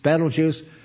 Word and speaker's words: Betelgeuse: Betelgeuse, or how do you pronounce it? – Betelgeuse.